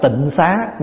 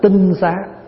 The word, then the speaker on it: tinh xá: Thì bây giờ chúng ta hay đọc là tịnh xá, nhưng mà chữ tịnh xá là đọc chưa đúng. Nếu đúng chữ là phải đọc là tinh xá.